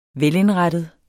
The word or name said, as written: velindrettet